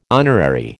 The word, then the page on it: honorary: American English